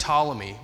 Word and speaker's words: Ptolemy: So, to elaborate a little bit more on that, the reason why this existed, this guy named Ptolemy, with a silent T at the beginning of his name, really cool name, throwing it on our list for a potential kid, Ptolemy II of Egypt, he commissioned 70 scholars.